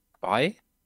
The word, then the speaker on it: BAE: Bei? – Bei. BAE. – Ach so. – Ja. Und es wird nicht bäh ausgesprochen.